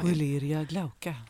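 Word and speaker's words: Koeleria glauca: Och sen så kommer tofsäxingen, kan du uttala det här nu då? Ja, du som Coleria glauca. – Ja, precis.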